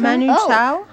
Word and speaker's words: Manu Chao: Eh ben, c'est Manu Chao. Ça se prononce comme ça? – Manu Chao. – Oh, oui. Manu.